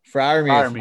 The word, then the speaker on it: Freiermuth: And then a guy, Penn State guy, here with my Penn State boys, Pat Freiermuth. Did I say that right? Freiermuth? – Freiermuth, my boy.